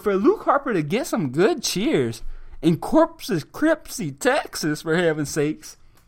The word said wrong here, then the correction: Corpus Christi: I don't even know how to pronounce it. Corpus Crispy? Corpus Crispy. Corpus Christi. Corpus Christi, Texas, which is also the worst crowd in the United States.